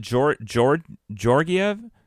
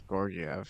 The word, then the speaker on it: Georgiev: And didn't they pronounce it Georgiev on the broadcast? – I thought it was Georgiev.